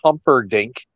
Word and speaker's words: Humperdink: And there was Sir Oliver Humpledink. I think they gave him a different name at the time. So, wow, yeah. You butchered that one. Big Daddy Dink is Sir Oliver Humperdink.